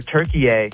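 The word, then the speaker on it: Türkiye: Well, apparently we all pronounce Turkey the country wrong, because in the World Cup, they're Turkey-ay and not Turkey.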